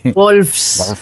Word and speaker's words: Wolves: que es Big Bad Wolfes. Eh, ¿Lo he dicho bien? Bueno, igual. – Big, Big, Bad Wolf. – es que esta es complicada. – Wolves.